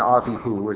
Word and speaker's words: Avihu: Chapters 8 through 10 have to do with the priesthood and entering into the holy, and Nadab and Abihu, or Nadav and Avihu, as they're pronounced in Hebrew, are pictures of how not to enter the holy.